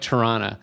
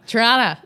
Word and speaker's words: Toronto: but you don't pronounce any of the T's. Well, except for the first one. Yeah, but it's like Toronto. – Toronto.